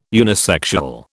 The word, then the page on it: unisexual: British English